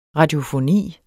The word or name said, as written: radiofoni